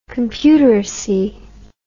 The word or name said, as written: computeracy